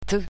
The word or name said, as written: t